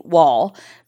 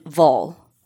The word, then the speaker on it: Wall: Like I said, this story takes place in Denmark and it focuses on two people Kim Vall, who the English pronunciation would be Wall, but she's Swedish. And I did watch a YouTube makeup tutorial on this by Paulina Beauty, and she's Swedish. And she said that it's pronounced Kim Vol and Peter Madsen.